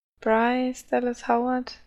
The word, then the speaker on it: Bryce Dallas Howard: Bruce Dallas Howard oder so. Bryce. – Bryce, glaube ich. – Bryce Dallas Howard, die ja auch aus Jurassic World und sowas kennen.